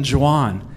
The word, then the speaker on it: Juan: Hey, I'm Juwan. Juan? No, it's Italian. It's spelled like Juan. It's spelled like Juan. But you pronounce it J because it's Italian.